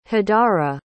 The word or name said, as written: hadarah